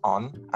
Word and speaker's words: An: An is pronounced on, and it just means the, mostly.